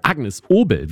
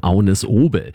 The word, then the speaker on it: Agnes Obel: Ich spreche von Agnes Obel, wie man sie denn auch auf Deutsch aussprechen kann. Aber auf Dänisch sagt man zu ihrem Namen Agnes Obel.